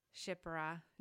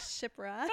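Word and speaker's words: Shiphrah: The midwives, the Hebrew midwives, Sephora. I'm reading it totally phonetically. – It looks like Shipra.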